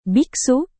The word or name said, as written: biksu